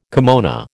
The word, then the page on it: kimono: American English